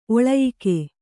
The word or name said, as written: ಒಳಯಿಕ್ಕೆ